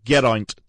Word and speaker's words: Geraint: Geraint Thomas, yes, that's how you say it, Geraint Thomas.